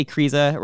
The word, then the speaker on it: Kryza: Andy Kriza, rhymes with Cheeza, of course, also on Thrillist, writes a lot, I guess.